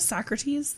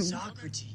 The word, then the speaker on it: Socrates: That Socrates, the guy mm-hmm. that played Socrates. – I'm sorry, it's pronounced Socrates. – Socrates.